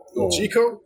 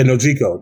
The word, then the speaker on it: Nojiko: Injuku or something? Let me see if I can go through the cast. Find her. – Nojiko? – Yeah, Nojiko. That's her name. – So, Nojiko.